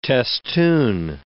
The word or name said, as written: testoon